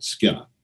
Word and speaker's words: ska: So they kind of invented ska, is how they pronounce it, like there's a Y in it, ska.